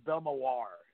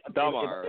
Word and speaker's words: Belmar: Yeah, I sort of pronounce it like Belmoir instead of Belmar, but okay. Maybe I'm Belmar.